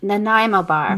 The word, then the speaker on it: Nanaimo bar: No, Nanaimo bar.